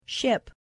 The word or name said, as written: ship